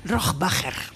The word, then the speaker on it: Rohrbacher: Rohrbacher.